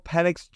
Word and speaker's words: Penix: Some people like to pronounce that last name a little bit differently. I'm going to stick with the way it's correctly pronounced in Penix.